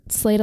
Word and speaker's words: Slade: It's spelled like S-L-A-D-E, but Slayed Alive.